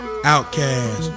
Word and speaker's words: Outkast: Outcast, pronounced outcast.